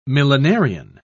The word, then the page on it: millenarian: British English